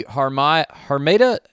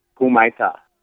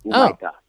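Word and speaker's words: Humaitá: How do we say that? The Harmeda? – We say Kumaita. – Kumaita. Is this some more of that confusing Brazilian alphabet where all the R's are H's? Is that what I'm messing up with my dumb American accent? – No, that's an actual H. – Oh, it is an actual H. Okay, there we go. – Yeah, okay. – It's pronounced as a almost a U sound. Kumaita.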